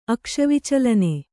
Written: ಅಕ್ಷವಿಚಲನೆ

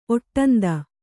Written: ಒಟ್ಟಂದ

